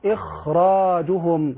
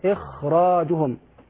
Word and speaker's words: إخراجهم: لوقوع الراء المفخمة بعدها، وخاء إخراج بتفخيم أتت من أجل راء بعدها إذ فخمت، كما قال الناظم ننطقها ونقرأها إخراجهم، هكذا إخراجهم.